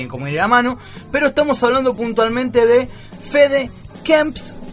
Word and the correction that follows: Kempf: Kempf, se escribe K-E-M-P-F-F